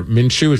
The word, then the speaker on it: Minshew: I even came across like a Gardner Minshew. Is that his name? – Yes. – How you pronounce it right?